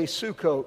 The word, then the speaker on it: Sukkot: All right, everybody say Sukkot. All right, easy to pronounce, Sue and Coat.